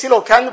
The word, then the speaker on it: coelacanth: It's the pronunciation is coelacanth, but it's spelled very differently. Person looks at the spelling, he'll be puzzled how to pronounce it.